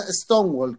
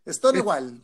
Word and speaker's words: Stonewall: Estamos en, Chile y no puede pronunciarlo como queráis, pero se llama Stonewall, como muro de piedra. – Eso, pues Stonewall. Pues, de última, no es Stonen, es Stone, Stonewall.